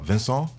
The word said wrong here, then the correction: Vincennes: Ah, Vincennes, yeah. Vincennes, see, I said Vincennes.